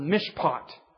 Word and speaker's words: mishpat: Here's the word mishpat, is the way you pronounce it.